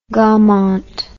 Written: Gaumont

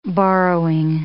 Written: borrowing

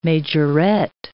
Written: majorette

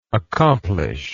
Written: accomplisher

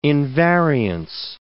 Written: invariance

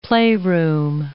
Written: playroom

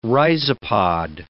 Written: rhizopod

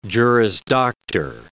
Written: Juris Doctor

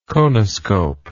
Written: conoscopic

